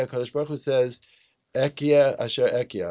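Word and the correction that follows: Ehyeh: I'm mispronouncing it. It's Ehya.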